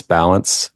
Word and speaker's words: Ballance: Yes, it's Nicholas Balance. Last name is spelled B-A-L-L-A-N-C-E.